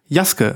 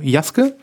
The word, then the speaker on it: Yasuke: Ähm, Jaske wird das ausgeschrieben, ausgesprochen. Okay. Schreibt sich Yasuke und genau, so heißt die Serie auch, also Jaske.